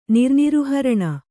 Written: ನಿರ್ನಿರುಹರಣ

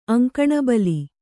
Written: ಅಂಕಣಬಲಿ

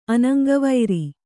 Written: ಅನಂಗವೈರಿ

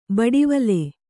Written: ಬಡಿವಲೆ

